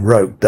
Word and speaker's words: Rooke: Roke, I think he pronounced his name, Roke, R-O-O-K-E.